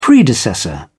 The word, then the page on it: predecessor: British English